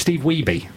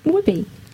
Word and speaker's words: Wiebe: Because you called it excellent, but yet you pronounced his name wrong. – Oh. – It's Steve Wiebe. – Watched it and they said Wibby.